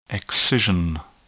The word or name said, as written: excision